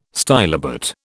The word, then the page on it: stylobate: British English